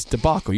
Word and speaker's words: debacle: It's debacle. You keep saying debacle. It's debacle.